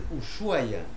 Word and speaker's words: Ushuaia: Algunos dicen Ushuaia con CH, Ushuaia, que se escribe así, ¿ya? – Hacemos la pausa comercial de las 9.